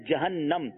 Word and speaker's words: جہنم: کچھ لوگ جہنم کہتے ہیں، یہ درست تلفظ نہیں ہے، لفظ ہے جہنم.